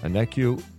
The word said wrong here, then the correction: Onyeka: And I think I butchered the pronunciation of his last name. – Okongwu? – I did what I haven't been doing. Onyeka. Onyeka! Okongwu.